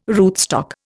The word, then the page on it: rootstock: Indian